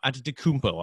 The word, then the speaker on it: Antetokounmpo: Antetokounmpo. I'm actually starting to pronounce his last name properly finally after how many years.